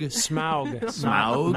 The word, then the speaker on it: Smaug: Smaug. Not like the pollution. It's like Smaug.